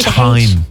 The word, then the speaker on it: thyme: You have to say thyme. – Thyme, yeah. – Yeah, thyme. – Well, then spell it T-Y-M-E.